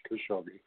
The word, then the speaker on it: Khashoggi: Kanogi. So, you know, it just, this is some of the things. It's like every Khashoggi, I'm sorry, not Kanogi, Khashoggi, I was mispronounced his name, that, you know, it's like talking out of both sides of the mouth.